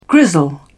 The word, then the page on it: grizzle: British English